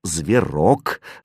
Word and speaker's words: зверок: «Нет, это зверок», — сказала Яна, улыбаясь, с ударением на «о».